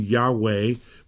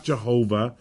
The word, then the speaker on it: Yahweh: You know, and uh, of course, it's the YHWH, which many would argue is not pronounceable um, there is a common pronunciation, Yahweh. We definitely know it's not Jehovah, and we know how that even came about.